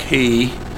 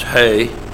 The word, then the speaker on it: He: I know it looks like He, but it's pronounced He, like H-E-Y.